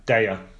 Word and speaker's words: Daiya: How do you say this one? D-A-I-Y-A. – Daya. – Daya. – No. – Yep. They've even got a pronunciation guide on their website. Daya.